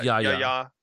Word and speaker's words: Yahya: It's pronounced Yahya.